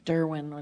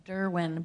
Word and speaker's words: Derouin: I was Derwin, which is French Canadian, and it's really hard to spell because it doesn't. – You were what? – Derwin, but it's spelled D-E-R-O-U-I-N.